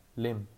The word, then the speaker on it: Liem: And the reason for that is because I'm born in Norway, and my name is actually, you say my name without the E is silent, so you say Lim, and that means glue, Lim,